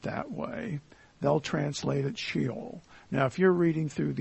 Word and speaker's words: Sheol: In the Old Testament, the meaning of the Hebrew word Sheol, and that's the Hebrew word, it actually is pronounced Sheol.